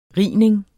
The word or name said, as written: rining